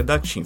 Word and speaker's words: Daqing: Isso mesmo, se escreve Daqing, mas pronuncia Datin.